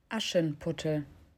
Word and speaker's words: Aschenputtel: Aschenputtel. – Aschenputtel? – Aschenputtel. – Aschenputtel? – Aschenputtel. – Aschenputtel. – Aschenputtel, spelt A-S-C-H-E-N. Mm-hmm. Aschen. – Aschen. – P-U-T-T-E-L. So puttel, but I think pronounced Aschenputtel.